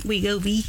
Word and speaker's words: Wegovy: Wegovy.